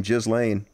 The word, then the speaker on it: Ghislaine: And first off, on tonight's Slow News Week with Dusty Smith, Ghislaine Maxwell, and that's how you pronounce her name, Ghislaine.